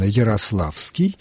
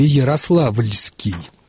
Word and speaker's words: Ярославский: Другими словами, как правильно «Ярославский» или «Ярославльский», «Рославский» или «Рославльский»? Правильны первые варианты. Суффикс «ли» исчезает.